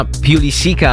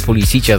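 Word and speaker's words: Pulisic: Tutaj Manchester United chce sprowadzić Christiana Pulisica, albo Pulisicia, zależy jak kto woli to wymawiać.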